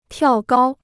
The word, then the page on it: 跳高: tiào gāo